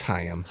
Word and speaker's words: Haim: Did you pronounce that correctly? – Haim. – Okay. – What did I say? Haim! – Haim? – Haim. – Haim Eshed. – It's Haim.